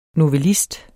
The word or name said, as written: NOVELLISTER